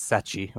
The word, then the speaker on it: Sete: It's it's the Portuguese word for seven. It's my name's Seth, but in Portuguese it's Sete or Seth.